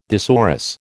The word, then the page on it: thesaurus: American English